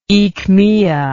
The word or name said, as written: aechmea